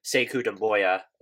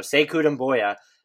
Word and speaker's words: Sekou Doumbouya: they took a project in Sekou Doumbouya, whose name I undoubtedly butchered there. – You got it. – All right, I'm still learning with these guys. I try to study up, but so Sekou Doumbouya.